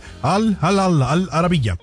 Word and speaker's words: Al Arabiya: Bueno, sabrá cómo se dice, pero es Al Arabiya.